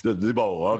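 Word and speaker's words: Daboll: So Debo, how you pronounce his name, the coach? – Brian Debo. Doesn't rhyme with Tebow. Debo.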